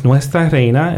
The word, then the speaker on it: Nuestra Reina: Again, it's Nuestra Reina. It's spelled N-U-E-S-T-R-A, Reina, which is R-E-I-N-A.